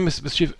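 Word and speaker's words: MSCHF: MS CHF, Mischief wahrscheinlich. Ja, klingt so. Klingt so, ne? Mischief.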